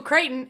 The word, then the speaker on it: Crichton: it would also say Michael Crichton, and then in brackets, rhymes with frighten.